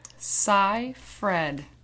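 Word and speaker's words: Seyfried: And this is how you pronounce Amanda's last name. Cy Fred.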